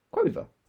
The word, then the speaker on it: quover: Quaver? Quover. – Quover. As in queer lover. Oh, quover then. – Quover.